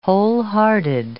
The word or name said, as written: wholehearted